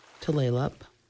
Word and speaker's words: Tulalip: Tulalip, Tulalip. The name is mispronounced by so many people, but Tulalip is nearest to the right pronunciation for the Snohomish Indian word Duflalap, which means the long bay.